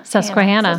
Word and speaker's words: Susquehanna: Susquehanna.